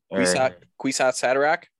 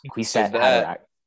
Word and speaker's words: Kwisatz Haderach: Kwisatz Haderach? – Yes. Kwisatz Haderach.